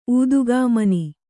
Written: ಊದುಗಾಮನಿ

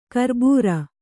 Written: ಕರ್ಬುರ

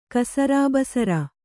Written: ಕಸರಾಬಸರ

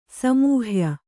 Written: ಸಮೂಹ್ಯ

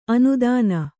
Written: ಆನೆದಿನ್

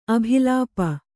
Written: ಅಭಿಲಾಪ

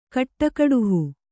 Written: ಕಟ್ಟಕಡುಹು

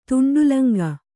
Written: ತುಂಡುಲಂಗ

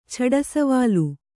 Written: ಛಡಸವಾಲು

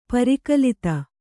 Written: ಪರಿಕಲಿತ

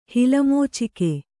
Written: ಹಿಲಮೋಚಿಕೆ

